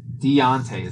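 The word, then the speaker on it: D'Onta Foreman: Donta Foreman. – Deontay is how you say that. – I like Donta Foreman. – It's because you're white. Deontay. – How do you... It's not, it doesn't end in an E.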